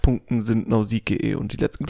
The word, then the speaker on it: Nausicaä: Bei 7 Nausicaä oder Nausicaä, wie hast du es ausgesprochen? Einfach Nausicaä. Ne? – Nausicaä. Ja.